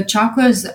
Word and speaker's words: chakra: So chakras, how it's spelled is C-H-A-K-R-A-S. And in the West, we say chakras, but it's actually chakra. That's how we pronounce it, chakra, originally.